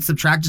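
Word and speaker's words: SBTRKT: And Subtract, just so y'all know, is S-B-T-R-K-T. I know it's spelled kind of weird, but that's, yeah, go check them out.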